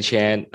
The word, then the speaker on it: Chan: Uh, and uh, my name is Andy Chan.